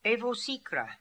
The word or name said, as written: Evosikra